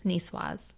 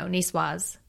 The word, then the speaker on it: niçoise: Yes, niçoise. – You say this is, it's not niçoise? – No, niçoise, because it has an E at the end, so it's female, so you say that, the S.